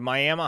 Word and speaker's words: Miami: Somebody said the tribe pronounces it Miami, so that's why it's pronounced Miami, which makes sense, but – you know, you think it maybe it could have been some Oklahoma lack of enunciation.